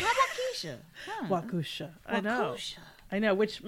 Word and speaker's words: Waukesha: It's pronounced Waukesha. – Waukesha? – Waukesha, not Waukesha. Thanks from a Wisconsinite.